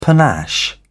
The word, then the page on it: panache: British English